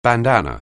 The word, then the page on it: bandan(n): anglais